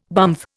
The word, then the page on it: bumf: Indian